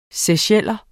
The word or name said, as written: seycheller